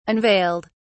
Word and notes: unveiled: British English

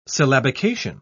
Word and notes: syllabication: British English

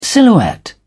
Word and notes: silhouette: British English

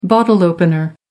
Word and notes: bottle opener: American English

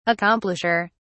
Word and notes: accomplisher: British English

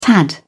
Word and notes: tad: British English

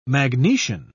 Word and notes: magnesian: British English

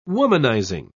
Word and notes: womanizing: British English

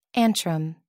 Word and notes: antrum: American English

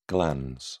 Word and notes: glans: British English

